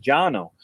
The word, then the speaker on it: Cano: And he put C-A-O. Now, his name's Jano Osgener, but Jano is spelled C-A-N-O, but it's pronounced Jano. So in 1968, he started C-A-O Pipe Company.